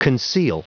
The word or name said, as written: conceal